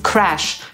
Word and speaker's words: crash: Słowo crush często jest mylone z crash, czyli z takim, które różni się tylko jedną literą i jednym dźwiękiem. Crash zapisujemy przez A, a wymawiamy przez tę samogłoskę między polskim A a E.